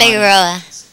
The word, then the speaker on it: Figueroa: Figueroa. – Oh my God, spell that one. – And what's your address? – F-I-G-U-E-R-O-A.